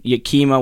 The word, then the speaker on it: Yakima: Chief Hops, Hop Master, and 100% grower-owned global supplier of hops in Yakima, Washington. Is that right? Am I pronouncing that right? – Yeah, you're good.